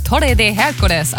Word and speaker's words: Torre de Hércules: Der sogenannte Torre de Hércules, also der Herkulesturm.